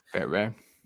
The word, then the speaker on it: Fairbairn: And then another bad signing by him, Kaimi Fairbairn. I don't know how to pronounce that name. – Fairbairn.